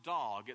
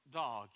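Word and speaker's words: dog (דג): The Hebrew word for fish is the word dog. It's spelled D-A-G. If you're taking notes, D-A-G. But it's not pronounced dag, it's pronounced dog in Hebrew.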